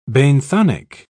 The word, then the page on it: benthonic: British English